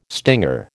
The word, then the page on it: stinger: American English